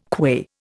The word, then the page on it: qua: Indian